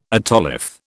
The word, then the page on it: otolith: British English